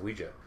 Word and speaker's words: Ouija: I've always said Ouija, which is weird. It is technically pronounced Ouija.